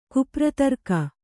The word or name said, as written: ಕುಪ್ರತರ್ಕ